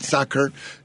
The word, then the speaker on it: Sacher: Well, you got the pierogies, but I also see that you got a soccer tort class coming up. Is that did I pronounce it right, Sacher? – Sacher tort, yes. Exactly.